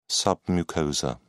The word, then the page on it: submucosa: British English